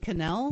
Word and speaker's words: Cannell: So Stephen J., is it Cannell or Cannell? – I've always pronounced it Cannell.